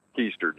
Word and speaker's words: Keister: It's Keister, just like falling on your...